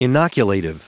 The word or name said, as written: inoculative